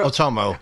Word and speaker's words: Otomo: is directing something at Studio M2 with Katsuhiro Otomo. How do you actually say his name? Katsuhiro Otomo.